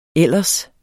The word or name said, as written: ellers